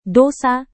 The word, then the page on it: dosa: Indonesian